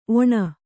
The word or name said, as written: ಒಣ